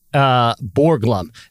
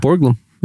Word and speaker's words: Borglum: Are you sure you said that right? – I'm gonna spell it for you. Gutzon. That's his first name. – Gutzon. – Uh huh. And then Borglum. Borglum. – Yeah, Borglum. Yeah. – So anyway,